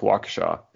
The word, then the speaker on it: Waukesha: It's a Native American name. – Oh. – A lot of the cities around here were, yeah, it's borrowed from Native, local Native American language. So yeah, that's how it's pronounced, Waukesha.